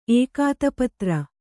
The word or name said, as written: ಏಕಾತಪತ್ರ